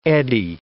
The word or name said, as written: eddy